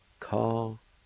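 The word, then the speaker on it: Ka-ga-li (ᎧᎦᎵ): The D-G-G-O-N-D. News. The D-G-G-O-N-D. Word number five is February, which we know is next month. It's pronounced like this. Ka-ga-li. Ka-ga-li. Ka-ga-li.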